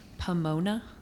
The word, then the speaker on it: Pomona: There, this holiday was called, or was to honor Pomona. I think I said that right.